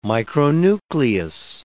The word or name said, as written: micronucleus